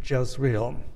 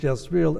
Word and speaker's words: Jezreel: And then the fourth result is in verses 21 to 23, the new meaning to the name Jezreel. In Hebrew, it's pronounced Israel with a Z, not an S sound like Israel.